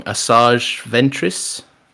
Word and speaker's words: Asajj Ventress: Asajj Ventress.